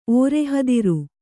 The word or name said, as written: ಓರೆಹದಿರು